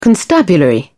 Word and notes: constabulary: British English

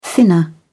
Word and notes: thinner: British English

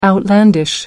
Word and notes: outlandish: American English

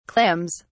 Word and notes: clams: British English

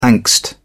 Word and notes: angst: British English

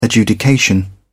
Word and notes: adjudication: British English

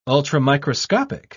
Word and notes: ultramicroscopic: British English

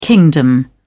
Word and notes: kingdom: British English

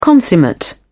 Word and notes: consummate: British English

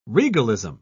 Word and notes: regalism: British English

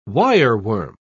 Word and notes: wireworm: British English

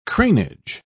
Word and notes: cranage: British English

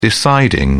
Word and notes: deciding: British English

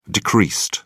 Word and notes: decreased: British English

